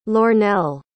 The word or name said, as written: Lornell